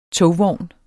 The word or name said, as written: togvognen